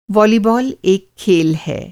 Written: वालीबाल

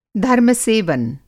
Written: धर्मसेवन

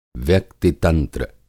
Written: व्यक्ति-तंत्र

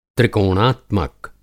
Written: त्रिकोणात्मक

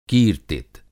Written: कीर्तित

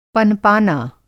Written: पनपाना

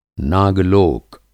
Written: नाग-लोक

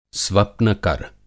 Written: स्वप्नकर

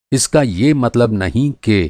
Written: मतलब

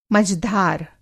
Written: मझधार

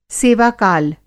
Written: सेवाकाल

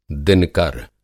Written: दिनकर

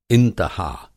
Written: इंतहा

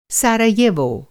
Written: साराजेवो

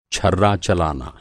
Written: छर्रा

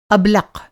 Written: अबलक़

